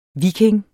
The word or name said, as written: viking